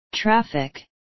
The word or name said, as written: traffic